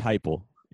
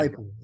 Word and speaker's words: Heupel: A testament to Josh Heupel. – Josh Heupel. – Is it Josh? – It's Josh Heupel. Yeah. – Heupel.